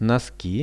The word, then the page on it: noski: Russian